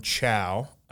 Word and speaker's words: Chao: So we hit the first one we have from Chow, and they even wrote, "My name is Chow, pronounced like Chow." There will be a distinction. Her name is spelled C-H-A-O, but she wants to make sure it's like pronounced like Chow, like C-H-O-W.